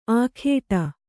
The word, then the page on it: ಆಖೇಟ: Kannada